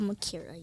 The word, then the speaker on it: Mukiira: It's hard to pronounce because it's a Kenyan last name, and I think my dad gave it to me because his last name is Mukiira, and so all my sisters have it too.